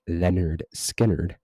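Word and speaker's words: Lynyrd Skynyrd: You listen to something like Freebird, which, you know, just a couple weeks ago I did an album review of the album pronounced Leonard Skinnerd, which has Freebird.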